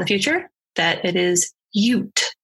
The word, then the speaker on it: Ute: So in the episode, I pronounced it as Ute, and it's actually Ute. – Oh. – Like Utah.